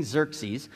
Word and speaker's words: Xerxes: And what happens is in the beginning of this book, the king, his name's King Xerxes, and Brianna killed it on that pronunciation.